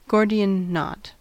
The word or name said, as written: Gordian knot